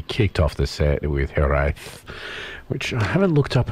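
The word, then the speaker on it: hiraeth: Well, I can tell you that Hereth. – Yes. – Is the way to pronounce it. Hereth.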